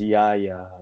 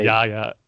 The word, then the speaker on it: Yahya: I mean, I think it's Yahya, right? – Yahya. Okay.